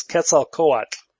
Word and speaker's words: Quetzalcoatl: It's Quetzalcoatl. – Quetzalcoatl.